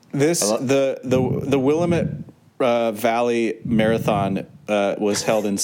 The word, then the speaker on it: Willamette: Willamette. – I believe it's pronounced Willamette.